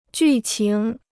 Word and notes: 剧情: jù qíng

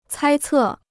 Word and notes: 猜测: cāi cè

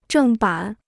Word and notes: 正版: zhèng bǎn

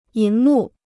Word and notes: 银幕: yín mù

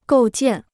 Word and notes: 构建: gòu jiàn

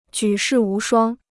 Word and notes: 举世无双: jǔ shì wú shuāng

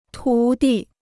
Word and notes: 徒弟: tú dì

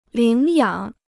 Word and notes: 领养: lǐng yǎng

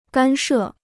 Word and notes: 干涉: gān shè

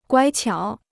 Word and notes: 乖巧: guāi qiǎo

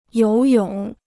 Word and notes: 游泳: yóu yǒng

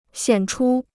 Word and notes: 显出: xiǎn chū

